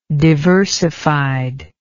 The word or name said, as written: diversified